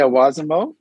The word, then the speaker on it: Wazimo: You made your first tech-focused acquisition, and I think it was November when you all announced the acquisition of, am I pronouncing it correctly, Wazimo? – Yeah, Wazimo, definitely.